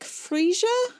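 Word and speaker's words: Frescia: His close friend, Nick Freesia? Like the flower, right? Even though it's spelled different? – Yeah, I think that's it.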